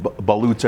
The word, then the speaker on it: balut: But brie and balutes. – Brie. I like brie. – Brie cheese. Mm-hmm. And explain to I've been very interested in balutes ever since I heard about them and saw something on TV about eating them.